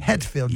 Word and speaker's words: Hetfield: You say het or hat? – Het. – Spell it. – H-E-T-F-I-E. – Hetfield. James Hetfield.